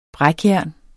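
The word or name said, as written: brækjernet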